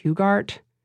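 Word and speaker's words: Hughart: It's like Hugart, like Hugh, A-R-T.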